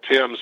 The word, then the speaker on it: Thames: spelled just like Thames in London, but they pronounce it Thames.